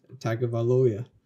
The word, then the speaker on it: Tagovailoa: Pronounce Tua's last name. – Tagovailoa. – Yeah, there it is.